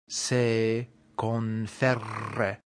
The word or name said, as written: se conferre